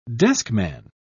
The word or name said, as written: deskman